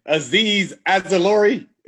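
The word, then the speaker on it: Azeez Ojulari: Azeez Ajulari, is that right? – Pronounced it perfectly.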